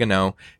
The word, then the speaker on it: An Oa: Spell that. A N mm-hmm. uh, O A. So two words. – So it's like a Scotsman saying, I know.